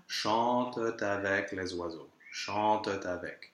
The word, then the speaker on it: chante avec: Now, here, chante is a verb in the third person of the plural, so that ENT ending is actually just a schwa with a silent T, which means that we can have the liaison after.